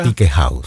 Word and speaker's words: Tikehau: Quella che ci ascoltiamo, Tikehau, è giusta la pronuncia? – Tikehau, sì.